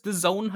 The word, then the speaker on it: DAZN: Ja, aber ganz, ganz kurz davor, äh, wusstest du, dass es The Zone heißt oder hast du das auch nur so bei Made My Day oder Faktastisch gelesen, dass es so ausgesprochen wird? Weil eigentlich geschrieben wird es ja D-A-Z-N.